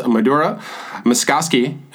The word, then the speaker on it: Madhura Maskasky: Let's get right to a guest because we have an extremely special guest with us today, and that is Madhura Maskasky.